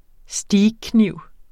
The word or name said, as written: steakkniv